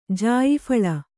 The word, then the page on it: ಜಾಯಿಫಳ: Kannada